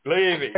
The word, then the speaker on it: Levy: Levy, yes, exactly.